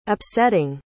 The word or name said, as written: upsetting